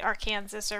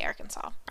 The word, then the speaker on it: Arkansas: You can't, it's not technically allowed to mispronounce it and say it Arkansas or however else you want to say it. You have to say Arkansas.